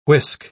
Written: whisk

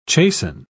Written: chasten

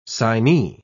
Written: signee

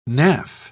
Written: naff

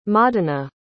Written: modena

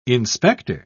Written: inspector

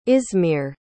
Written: izmir

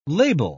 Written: labile

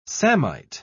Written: samite

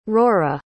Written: rora

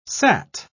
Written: sat

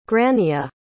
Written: grannia

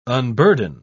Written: unburden

